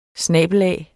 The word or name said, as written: snabel-a